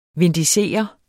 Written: vindicere

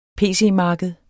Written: pc-marked

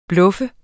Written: bluff

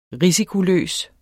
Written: risikoløs

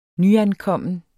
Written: nyankommen

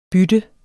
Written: bytte

